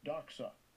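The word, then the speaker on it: doxa (δόξα): Strong's G1391, doxa. Doxa.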